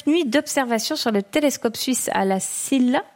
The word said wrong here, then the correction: La Silla: À la SIA.